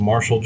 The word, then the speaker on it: Marshal: For everybody out there, you can find me underneath of Marshall Johnson.